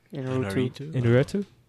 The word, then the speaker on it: Iñárritu: How do you pronounce that? – Iñárritu.